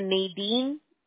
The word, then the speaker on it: Nadine: So, si puedes apuntar mi número de teléfono y nomás dile que Nadine, le doy mi nombre, te lo voy a letrear, ¿ok? – Ok.